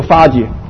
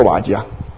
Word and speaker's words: 华: 咁呢、这个讲出呢个莲花系报身如来所住呢个国土嘛，咁系应该读作呢个花字，唔系读作呢个华字啊？